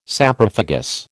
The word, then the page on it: Saprophagous: American English